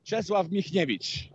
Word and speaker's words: Czesław Michniewicz: Czesław Michniewicz.